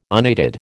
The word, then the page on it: unaided: American English